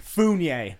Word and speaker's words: Fougnies: Founies. F-O-U-G-N-I-E-S.